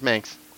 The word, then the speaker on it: Manx: Myers Manx. Rhymes with Spanx.